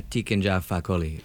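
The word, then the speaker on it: Tiken Jah Fakoly: And then the second song we're going to listen to is going to be, all right, I'm going to butcher it, but Tiken Dja Fakoly. Is that how you say it? – Yeah, Tiken Dja Fakoly.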